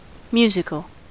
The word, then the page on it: musical: American English